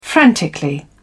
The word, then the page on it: frantically: British English